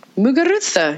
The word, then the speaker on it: Muguruza: Muguruza.